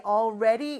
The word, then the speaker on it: already: 그런데 이 already 이라는 단어에 어려운 발음이 다 들어있어요. all 할 때 그 L 발음과 re 할 때 그 r 발음, 그 다음에 d 발음은 우리의 리을과 비슷한 발음이 돼요.